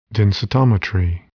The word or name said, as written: densitometry